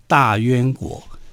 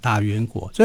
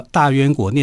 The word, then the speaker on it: 大宛: 然后他就想让这个李广利好能够去向卫青霍去病那样好去建功立业，就让他去打西域一个国家叫大宛国。大宛这个字啊，如果我们写成国字的话是大宛，嗯哼，啊大宛国。啊。可是古音的话，我们要读成渊哈，大渊国。所以很多人习惯把这个大大渊国念成大宛国哈。其实我们如果读古音，这个字要读作渊。